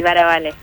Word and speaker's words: Baravalle: Bien presentado, el apellido está bien, ¿Baravale o Baravalle? Me quedó esa duda. – La pronunciación sería así, Baravale. – ¡Baravale! – Claro. – Para hablar sí. La italiana.